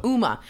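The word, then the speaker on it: Huma: While looking for penis pictures, they found some Yuma, is that am I saying it right? – Uma. – Right. Oh, like Uma Thurman.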